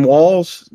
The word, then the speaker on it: Walz: Kamala Harris, she announced her VP pick, which is Tim Walz.